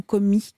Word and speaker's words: Comey: Pardon pour la petite précision, on dit Comey ou Comey ? Comment vous le prononcez ? – En français, on peut dire les deux.